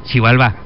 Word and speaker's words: Xibalbá: ¿Cómo se dice? ¿Xibalba o Xibalbá? Xibalbá.